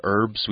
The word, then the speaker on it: herbs: It has herbs, and you'll notice here we don't really pronounce the H in herbs. We just kind of say herbs. – Unless you're from Great Britain. – They say herbs. – They say herbs. It drives me nuts. – But in the United States, we often just say herbs without the H. – No H. – Almost like it's spelled E-R-B-S.